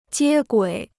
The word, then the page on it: 接轨: jiē guǐ